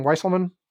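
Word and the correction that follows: Weisselberg: What? Weiselberg. – Weiselberg.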